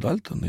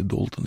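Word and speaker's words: Dalton: Ну вот Джон Дальтон, или Долтон, если более правильно говорить, один из основателей современной химии, ну, вообще считали неотесным мужланом.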